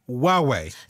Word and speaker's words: Huawei: And this week it's a flagship Chinese smartphone pronounced Huawei.